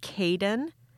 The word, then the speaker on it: Cayeiaden: And then this this one is Caden, but it's spelled C-A-Y-E-I-A-D-E-N.